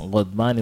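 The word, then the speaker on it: Ghadba (غضبى): So the feminine word for this Ghadban is going to be what? – Ghadba. – Ghadba. Ghain. Dodba. Then the ya that is pronounced like alif. Ghadba.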